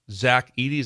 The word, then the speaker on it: Zach Edey: advisor for Zach Edey. Is that correct? Am I saying the name right? – Yes.